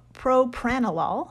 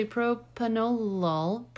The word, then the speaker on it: propranolol: They even threw in some propranolol. I think I'm saying that right. – It's actually propanolol. Propranolol.